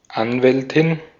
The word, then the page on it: Anwältin: /ˈanvɛltɪn/